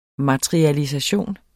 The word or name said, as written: MATERIALISATIONERNES